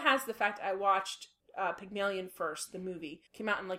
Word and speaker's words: Pygmalion: So my movie is Pygmalion, and I actually checked to see if I was pronouncing it correctly, because just because. And I am.